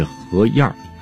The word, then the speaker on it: 河沿: 后海边上的沿岸叫做河沿，那这个词就像我刚才读的，在咱北京话当中被读作是河沿。